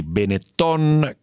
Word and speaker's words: Benetton: i guadagni che finora sono andati ai Benetton, con l'accento sulla O, adesso presumibilmente andranno allo Stato, andranno a Cassa Depositi e Prestiti, che diventerà azionista.